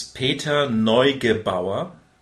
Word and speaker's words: Peter Neugebauer: It is Peter Neugebauer, Peter Neugebauer, which reads like Peter Neugebauer,